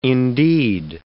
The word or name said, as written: indeed